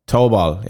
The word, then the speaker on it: Tobol: Toba. – Who? – How do we pronounce this? – Is it not Tobol? – Tobol, yeah.